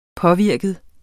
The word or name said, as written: påvirket